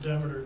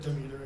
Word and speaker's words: Demeter: It is pronounced Demeter, it doesn't matter. But you can say Demeter if you want, it's okay.